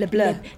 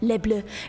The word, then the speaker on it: Le Bleu: Guys, what do you think about my French pronunciation of Le Bleu? – I didn't like it. – Really? – Would you tell me, like, how should I do it? – Flourish, you know, like French flourish.